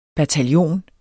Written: bataljon

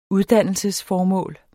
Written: uddannelsesformål

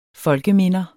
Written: folkeminder

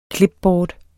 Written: clipboard

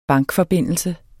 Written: bankforbindelse

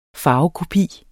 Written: farvekopi

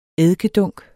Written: eddikedunk

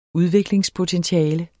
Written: udviklingspotentiale

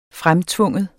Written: fremtvinge

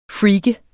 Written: freak

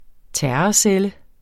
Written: terrorcelle

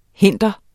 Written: hinder

